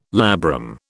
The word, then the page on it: labrum: British English